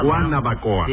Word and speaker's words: Guanabacoa: Yo, porque no se dice Guanabacoa, sino Guanabacoa.